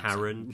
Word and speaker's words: Charon: Charon, however you want to pronounce Karen. – It. – Charon.